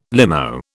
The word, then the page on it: limo: British English